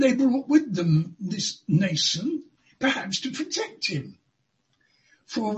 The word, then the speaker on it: Mnason: Well, never mind about that, but I rather suspect you don't pronounce the M in this name. The name is Mnason of Cyprus.